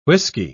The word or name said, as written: whisky